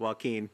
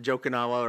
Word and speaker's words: Joaquin: Joaquin. – Neiman. – Not Jokinawa or whatever you said.